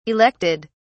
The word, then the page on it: elected: British English